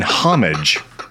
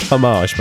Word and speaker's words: homage: Or if you're like some of our co-hosts, it'd probably an homage. I believe that's how they say it over there. – It's pronounced homage, by the way.